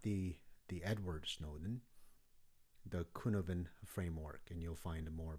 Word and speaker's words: Cynefin: That's the Cynevin framework that spells C-Y-N-E-F-I-N, but you pronounce it as a Cynevin. It's a Welsh word that signifies the multiple factors in our environment and our experiences that influences us in ways we can never understand.